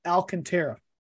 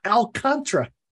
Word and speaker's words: Alcantara: His name is not pronounced Alcantara. I was watching the Marlins and the Dodgers today. It's Alcontra.